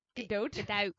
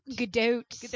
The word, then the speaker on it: Gadot: Gadot. – Gadot.